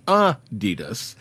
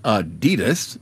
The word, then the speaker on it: Adidas: From our You've Got to Be Kidding Me file, apparently we've been pronouncing Adidas wrong all this time. It's supposed to be Adidas, with a slight emphasis on the first syllable, not Adidas, stressing the second.